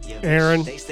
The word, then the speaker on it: Erin: D-E-M-O-N-D. – Demond. – Demond. – What's your middle name, Thomas? – Aaron. – Aaron. – Mm-hmm. Like Aaron Carter. – two A's? – No, E-R-I-N, spelled like a girl.